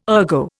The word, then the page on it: ergo: Indian